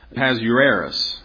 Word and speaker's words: Ahasuerus: A few key things to remember in this, and I always butcher these names, but the king's name here is pronounced Ahasuerus, is the way you pronounce his name.